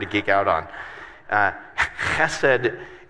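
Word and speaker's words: chesed (חֶסֶד): Um, it, it's the, the Hebrew word chesed. So say chesed, but kind of say it like you're kind of clear your throat, right?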